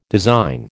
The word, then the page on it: design: American English